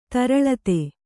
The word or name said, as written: ತರಳಿತ